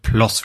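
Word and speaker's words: Ploß: Auf jeden Fall hat, hat der oh Gott, Ploss wieder ausgesprochen, obwohl er Plos geschrieben wird, wo er mhm.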